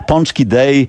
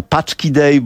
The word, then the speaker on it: pączki: Tak jak u nas jest tłusty czwartek, to tutaj we wtorek jest wtedy Mardi Gras wypada i Polonia amerykańska obchodzi pączki day, Amerykanie mówią paczki day, bo nie wiedzą, że ten ogonek to się wymawia on.